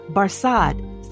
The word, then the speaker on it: Barsad: "Barsad," said Defarge, making it French by pronunciation, but he had been so careful to get it accurately that he then spelt it with perfect correctness.